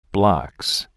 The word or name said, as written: blocks